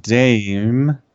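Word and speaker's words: DxE: Vegan activists who identify themselves as members of Direct Action Everywhere Melbourne, which is pronounced DAME.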